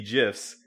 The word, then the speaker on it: GIF: I pronounce it GIF because I'm a big fan of romance vowels. That is A-E-O-U. – Okay. – So I think the I should be pronounced E.